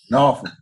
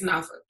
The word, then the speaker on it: Norfolk: Norfolk. Don't pronounce the L. – It's not there. It's Norfolk.